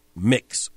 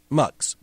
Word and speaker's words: Mx: That is the prefix MX, rather than Mr. or Mrs. or Miss or Ms. Mix, or pronounced, at least in some suggestions, mux, but mix appears to be the prevailing pronunciation.